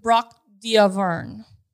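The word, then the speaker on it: Braque d'Auvergne: It's French. So if you could speak France at us. – Brock d'Auvern. Auvern.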